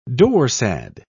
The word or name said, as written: dorsad